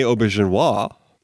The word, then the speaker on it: Auberjonois: Oh, yeah, and then of course, 80s TV actor, and then later into the 90s on Star Trek, Rene Auberjonois.